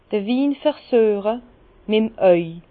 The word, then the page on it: d'r Win versüeche mit 'em auw: Bas-Rhin; Herrlisheim